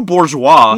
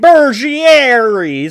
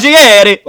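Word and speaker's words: Burguiere: I'm just gonna pronounce it the way it looks like it's spelled. Stu Bourgeois. – No, Stu Bougieri. Bergieri. He's a fucking Italian. – Bergieri.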